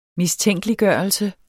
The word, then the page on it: mistænkeliggørelse: -ˌgɶˀʌlsə